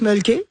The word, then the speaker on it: Mölkky: Ça, on prononce Mölkky. – Ouais. – Et c'est M-O-L-K-K-Y.